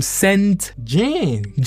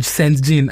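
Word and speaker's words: SAINt JHN: So, I'm going to end the show with a song from Saint Jean. – Saint Jean. I didn't know how to pronounce it. I was like Saint J-H-N.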